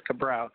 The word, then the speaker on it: Cabral: It's Cabral Clements. Are you there? – Yes, Cabral. Can you hear me? – Cabral. Oh, man, I'm sorry. Cabral. I got it now.